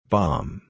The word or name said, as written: bomb